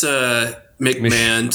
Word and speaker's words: McMahon: Oh, it's McMahon. Two syllables. Seeing other people spell it same way and say it otherwise, but I guess nobody actually cares about that.